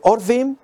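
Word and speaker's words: Orvim (עורבים): Pueden pronunciarse Orvim y significan cuervos. Cuando se pronuncia Arvim, significan árabes.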